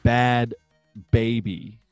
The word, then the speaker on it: Bhad Bhabie: So the Catch Me Outside Girls rap name is Bad Baby. – Okay, yeah. Like not her. – But it's spelled B-H-A-D-B-H-A-B-I-E.